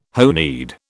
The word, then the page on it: honied: British English